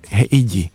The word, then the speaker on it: Heidi: Ano, heidi. Jo, Heidi se to ale vyslovuje, jako Heidi domů, tak Heidi Janku. – Ne, jako Heidi Klum.